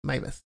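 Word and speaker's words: Meiwes: El caso del alemán Armin Meibes, que se pronuncia uh, Armin Maives.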